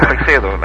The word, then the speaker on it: Freixedo: Is that how you pronounce it? We all said Freixedo. – Well, Freixedo, actually. – Oh, Freixedo. Freixedo, because he's from Spain. – Well, he's from Galicia.